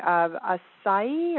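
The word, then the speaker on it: açaí: And then this Brazilian berry, acai, or how do you pronounce that one? – Acai. – Acai.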